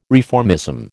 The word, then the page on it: reformism: American English